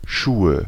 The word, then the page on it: Schuhe: /ˈʃuːə/